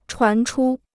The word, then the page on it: 传出: chuán chū